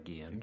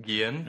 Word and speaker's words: Guillen: Jose Guillen, I'm probably mispronouncing that. – I think it's probably Guillen. – Guillen.